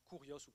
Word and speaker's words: Kyrios: Mais la Bible nous dit, il faut que tu acceptes que Jésus est, en grec, le Kyrios, K-Y-R-I-O-S, qui se prononce Kurios ou Kurios en grec.